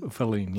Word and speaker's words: Faline: Faline, yes.